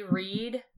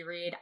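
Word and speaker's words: Wrede: It's W-R-E-D-E, Patricia C. Reed. I highly recommend her books. I enjoyed them immensely as a 12-year-old. I'm so sorry. I just got her name wrong, like, a million times. In my defense, it was a W-R name.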